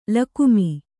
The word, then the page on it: ಲಗಾಮು: lagāmu